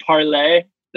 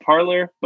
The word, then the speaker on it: Parler: We're going to talk about Parlay, the right-wing social media app that should just be pronounced Parler, but for some reason they went with the French pronunciation to fuck with their base.